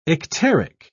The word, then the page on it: icteric: British English